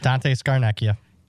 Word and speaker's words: Dante Scarnecchia: Starnecchia, is that how you say it? – Yep, Dante Scarnecchia.